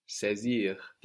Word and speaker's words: saisir: This A I gives us E, like in nécessaire, saisir. And we have the S, S A I S I R, pronounced like a Z, saisir.